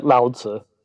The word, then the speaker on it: Lao Tse: you talk mainly about three main teachers, Confucius, Lao Tse, did I say that correctly? – Yeah, Lao Tse.